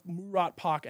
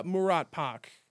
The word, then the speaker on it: Murat Pak: Like, I bought a lot of Murat Pak NFTs. You heard of those, the blank pages? – I'm looking at them now. What are they called? – Well, just look up Murat Pak.